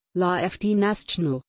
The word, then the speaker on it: La Fête Nationale: In France, it is formally called La Fête Nationale, French pronunciation, La Fête Nationale, the national celebration, and commonly and legally Le 14 Juillet, French pronunciation, El Quatsage, the 14th of July.